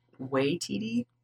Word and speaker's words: Waititi: Korg wears the same Hawaiian t-shirt that Taika Waititi wore at Waititi.